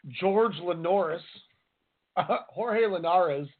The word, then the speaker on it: Jorge: In his post fight interview, Lundy called for a shot at WBA ring lightweight champion Jorge Linares, which he pronounced George Linares.